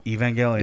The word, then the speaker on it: Evangelion: Evangelion. – It's a hard G.